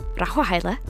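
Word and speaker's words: Rahawa Hale: But you can find me on Twitter, @RahawaHale, or Rahwahaila for the Abisha folks. We had a conversation about how to pronounce my name, and we can get into that another time, but it's at Rahwahaile.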